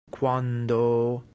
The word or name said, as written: quando